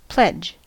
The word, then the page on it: pledge: American English